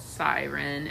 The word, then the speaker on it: Syren: I have a song I'm releasing, and it's called Siren, and it's spelled S-Y-R-E-N.